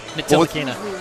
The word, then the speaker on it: Ntilikina: Ntilikina. – Ntilikina.